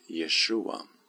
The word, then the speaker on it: Jeshua: Och Jesus är ju den grekiska formen av Jeshua som betyder Herren frälsningen eller Herren frälser och uttalades på hebreiska som just Jeshua.